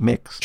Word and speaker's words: Mx: Well, yes, it's MX. It's pronounced like Mix.